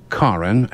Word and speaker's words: Karen: And it is pronounced Karen and not Karen.